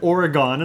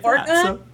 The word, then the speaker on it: Oregon: You said Oregon, and I didn't say a word about that. That's not how you say it. – Oregon? – Oregon. – Oregon.